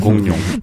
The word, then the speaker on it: 공룡: 공, 룡 아예 포기하고 니은으로 발음하세요.